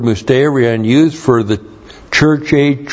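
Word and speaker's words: Musterion (μυστήριον): The Greek word is musterion. M-U-S-T long E-R-I-O-N. Musterion.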